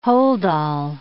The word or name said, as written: holdall